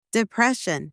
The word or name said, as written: depression